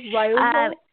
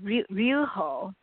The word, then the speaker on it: Ryuho: His name Ryuho. – Actually, it's very difficult. Ryuho. Ryuho. – Oh, Ryuho. Ryuho. – Yeah, Ryuho. Yeah. So it's almost like you're not pronouncing Y. – Ryuho.